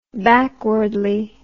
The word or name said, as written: backwardly